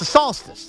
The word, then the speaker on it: solstice: I like how you're pronouncing solstice like salsa. – It's the solstice, the summer solstice.